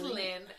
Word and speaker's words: Lyn: My middle name is Lynn and it's spelled L-Y-N.